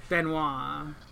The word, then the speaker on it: Banoi: B-A-N-O-I. Don't know how you say it. – Benoit.